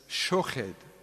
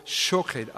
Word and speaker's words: Schochet: Das heißt Schochet, wenn kam jetzt lang A als O, Schochet ausgesprochen. Ich sehe ein Schochet, einen Mandelzweig.